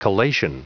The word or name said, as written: collation